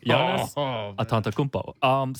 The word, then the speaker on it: Giannis Antetokounmpo: Did I pronounce his name right? – Giannis Antetokounmpo.